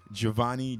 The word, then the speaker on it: Jovoni: At Monsieur Giovanni. – Spell it. – Spell it. – That's MONSIEUR Giovanni, JOVONI.